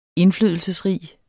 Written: indflydelsesrig

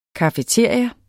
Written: cafeteria

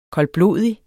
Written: koldblodig

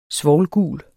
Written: svovlgul

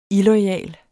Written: illoyal

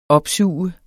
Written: opsuge

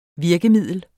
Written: virkemiddel

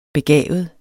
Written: begavet

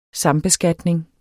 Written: sambeskatning

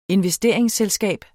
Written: investeringsselskab

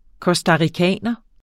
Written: costaricaner

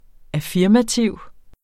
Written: affirmativ

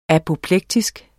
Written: apoplektisk

